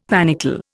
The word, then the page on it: panicle: Indian